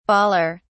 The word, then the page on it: bawler: British English